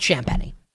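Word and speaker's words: Champagnie: That should be how you pronounce his name, but it's Champagny.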